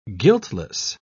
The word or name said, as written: guiltless